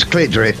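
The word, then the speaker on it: CLT DRP: They are spelt in capital letters CLT space DRP, and very helpfully on their Bandcamp page they put pronounced clit drip.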